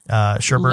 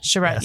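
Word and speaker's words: Chabert: Shabret. I don't know. – Sherbert. – Lacey kind of looks like Jennifer Love Hewitt. Shabret, yes.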